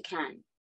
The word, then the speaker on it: Cannes: But you mentioned about the film doing so well in, is it Cannes? – Yeah, it's Cannes. – We were debating how you say it before, because we're not as glamorous as you, so we've never been. – I think that sounds right. You know how it's spelt with an S, but it's Cannes.